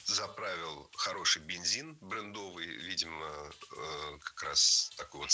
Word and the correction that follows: брендовый: Брендовый – это неверно. В этом слове ставим ударение на первый слог – брендовый. Бренд – брендовый. Сохраняем ударение исходного существительного. Кстати, это слово несколько лет назад вошло в словари, и ударение в нем уже нормированное – брендовый.